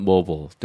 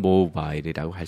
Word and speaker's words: Mobile: 발음은 모블 또는 모바일이라고 할 수 있는데 이때 모라는 것이 움직인다는 뜻에서 여러분의 휴대폰은 움직이니까 휴대폰 이동하니까 휴대폰입니다.